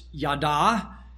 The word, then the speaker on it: yada: The Hebrew word confess is pronounced yada, and there's a stem in the Hebrew language that means back and forth, okay?